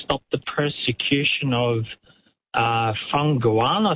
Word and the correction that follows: Falun Gong: I think it's pronounced. – Falun Gong it'll be.